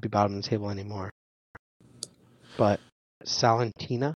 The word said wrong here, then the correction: Salernitana: Is that how you say it? I always say it wrong. – Salernitana. I'm gonna get it one day. – Salernitana. Thank you.